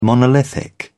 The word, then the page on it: monolithic: British English